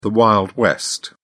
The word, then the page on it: wild west: British English